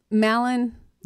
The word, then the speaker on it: Mallon: If you want to connect with Thies, just so you know, the way to spell it is T-I-S-S-E. – Correct. – Great. – Yes. – M-A-L-L-O-N. – Yes. Malin, like Malin. Gallon. It's like gallon, but with an M.